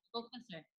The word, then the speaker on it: Sylvester: Yeah, Sylvester.